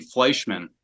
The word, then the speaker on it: Fleischman: Oh, it's actually Fleischman. – Okay. – Kind of like the vodka, but it's spelled a little different. It's one N, not two.